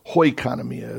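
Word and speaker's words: hoikonomia (οἰκονομία): The English Standard Version uses the word plan, but it is the Greek word here, oikonomia, or hoikonomia is actually how I pronounce it in Greek.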